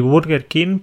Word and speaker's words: Burger King: Vale, entonces decimos McDonald's y Burger King porque la U la pronunciamos como la vemos. – Vemos una U ahí para burger, entonces burger.